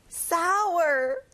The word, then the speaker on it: sour: 신, 시큼한, sour. 여러분, 아우 할 때 입을 크게 벌려보세요. Sour. That's right.